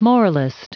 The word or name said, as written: moralist